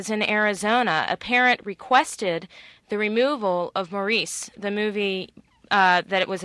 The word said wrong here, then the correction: Maurice: Morris is actually how it's pronounced, which is a book about teenagers in high school in England.